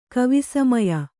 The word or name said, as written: ಕವಿಸಮಯ